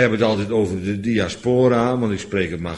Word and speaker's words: diaspora: En ik geloof dat het fout is, want dat het moet zijn diaspora. Nou ja, geen Engelsman die het zo zal uitspreken, maar het is ook geen Engels.